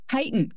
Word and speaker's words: chitin: Is it chitin? Chitin? – Chitin. – Chitin. Okay.